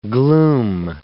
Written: glume

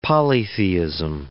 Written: polytheism

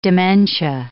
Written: dementia

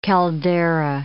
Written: caldera

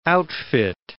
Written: outfit

